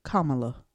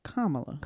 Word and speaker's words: Kamala: It is Kamala. – Kamala. Okay. Not Kamala. It's right. Kamala. – Kamala. – The emphasis is on the second A.